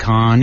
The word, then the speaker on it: Cannes: Well, Con is how they say it.